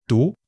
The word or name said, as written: du2